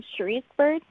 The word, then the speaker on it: Cherice Byrd: Yeah, it's Cherice Byrd.